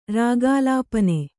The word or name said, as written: ರಾಗಾಲಾಪನ